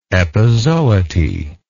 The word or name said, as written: epizooty